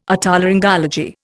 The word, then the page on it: Otolaryngology: Indian